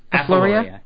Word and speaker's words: Ephaleria: So this particular group, this genus Ephaleria, is my saying it right? – Ephaleria. – Ephaleria. Oh, that sounds so much prettier.